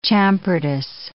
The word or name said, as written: champertous